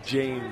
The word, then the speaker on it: Jane: So the J is pronounced, I thought it was Hain. It's Emilio Jane, number 5 for Summit.